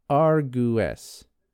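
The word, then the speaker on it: argẃes: It is spelled A-R-G-W-E-S. Now, the W has one of those angled accents over it. So A-R-G-W accented E-S is pronounced Argues. Does that make sense? So the W is like its own oo sound.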